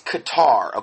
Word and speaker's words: Qatar: He pronounces it Qatar. Yeah, Qatar. No, it's Qatar, okay? It's not pronounced that way. It's Qatar. It's Q-A-T-A-R.